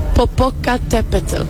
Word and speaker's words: Popocatépetl: Popocatépetl.